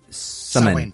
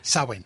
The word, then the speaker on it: Samhain: Samhain, am I pronouncing this correct? Is that the way you would pronounce... – Think of it like summer's end, summon. – Samhain. – Put a W in. Samhain.